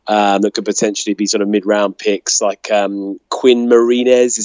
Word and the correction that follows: Miners: Is that how you pronounce it? From Wisconsin Whitewater. Is that right? – No, Quinn Miners. – Quinn Miners. – Yeah.